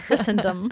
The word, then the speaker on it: Christendom: They're Christendom. – Christendom. – The T is not pronounced.